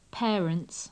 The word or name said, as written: parents